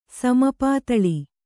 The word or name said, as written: ಸಮಪಾತಳಿ